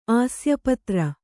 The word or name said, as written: ಆಸ್ಯಪತ್ರ